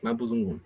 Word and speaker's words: Mapuzungun: ¿En la pronunciación oficial es Mapuzungun, no Mapuzungun. Así es. Mapuzungun.